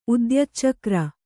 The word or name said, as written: ಉದ್ಯಚ್ಚಕ್ರ